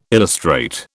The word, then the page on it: illustrate: British English